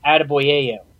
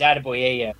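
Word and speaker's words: Adeboyejo: But if you look at his name, and I kind of gave him the nickname Attaboy because his name is Adeboyeyo. That's how you pronounce it.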